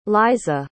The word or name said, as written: liza